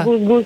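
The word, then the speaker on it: GusGus: Gus Gus, ja to zapamtim, zato je to nastalo od Kus Kus.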